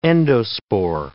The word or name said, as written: endospore